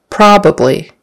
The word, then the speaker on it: probably: Probably, we use that a lot in conversation. Make sure the accent is on the first syllable, probably, and that it's a short O for the O, probably, not probably, but probably, The ah sound, your tongue or your mouth opens like this, probably.